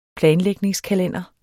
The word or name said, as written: planlægningskalender